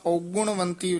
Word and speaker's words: ਔਗੁਣਵੰਤੀ: इसी तरह औगुणवंती उच्चारण करना है ਊੜੇ ਦੇ ਵੀ ਔਂਕੜ ਹੈ, ਗੱਗੇ ਦੇ ਵੀ ਔਂਕੜ ਹੈ। ਔਗਣਵੰਤੀ ਨੀ ਕਹਿਣਾ, ਔਗੁਣਵੰਤੀ ਝੂਰ।